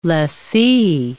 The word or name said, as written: lessee